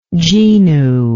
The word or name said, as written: genual